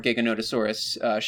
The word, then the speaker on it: Giganotosaurus: Oh, yeah, well, the skull is a lot more Giganotosaurus shaped. – Uh-huh. You know what? That's a much better pronunciation. – Gigantosaurus really is exactly how I want to pronounce it every single time I look at it.